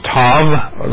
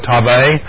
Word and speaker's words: Tav: which is Tav, like or Tave, which many times that you'll see that pronounced.